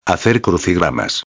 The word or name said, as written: hacer crucigramas